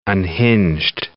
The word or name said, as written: unhinged